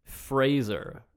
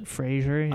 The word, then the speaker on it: Fraser: It is, in fact, Fraser, like a laser.